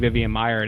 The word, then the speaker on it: Vivian Maier: And then all of a sudden, I mean, great example, Vivian Maher, the photographer. – Yeah, Vivian Maier is great. – Did I say that right? Okay.